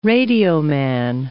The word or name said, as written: radioman